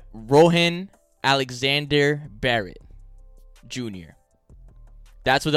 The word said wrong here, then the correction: Rowan: I think that's how you pronounce it. R-O-W-A-N. Rowan.